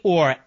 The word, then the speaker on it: Ohr: Orr, O-H-R, that's how you spell it, held two titles at the Department of Justice, Associate Deputy Attorney General.